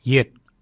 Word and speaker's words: yed: Nanak is spelled N-A-N-G-A-G. Nanak, nanak is your neck. Chin, we say yet, yet for chin. Yet is spelt Y-E-D. Yet. Yet is your chin. For throat, we say wort. For throat, we say wort. Wort is spelt W-O-O-R-D.